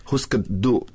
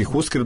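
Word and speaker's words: Hüsker Dü: Jeste, moja čerka je pre neki dan ukucala to i na Wikipediji ima onaj izgovor i kaže se Husker Du, čak se ovo ni Du ne čuje baš. Znači Husker Du je definitivno, da, to je danski i oni izgovaraju Husker.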